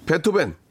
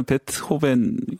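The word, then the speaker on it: Beethoven: B E E T H O V E N입니다. 그래서 베 그대로 읽으면 베트호벤. 베트호벤. 예. 베트호벤. 다시 한번 해주기 독일 독일식 발음을 해주시기 바랍니다. 베트호벤. – 베트호벤. 예예 예, 알겠습니다. 독일식 발음으로 다시 한번요. 베토벤.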